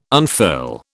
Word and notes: unfurl: British English

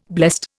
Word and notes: blessed: Indian

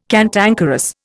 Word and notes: cantankerous: Indian

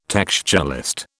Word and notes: textualist: British English

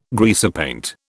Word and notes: greasepaint: British English

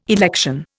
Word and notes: election: Indian

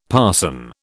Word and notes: parson: British English

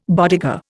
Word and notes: bodega: Indian

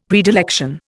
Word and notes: predilection: Indian

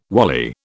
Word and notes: wally: British English

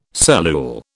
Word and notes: cellule: British English

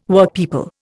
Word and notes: Workpeople: Indian

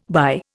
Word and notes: buy: Indian

